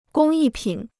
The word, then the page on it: 工艺品: gōng yì pǐn